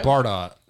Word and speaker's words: Bardot: Oh, Bridget Brodar. I don't even think I pronounce it right. – No, it's Bridget Bardot.